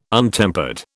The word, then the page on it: untempered: British English